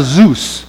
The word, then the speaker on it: Zeus: That's a Zeus. It rhymes with goose. So remember that.